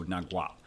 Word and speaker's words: Nagual: it was recommended we pronounce it is with the G noise, so Nagual.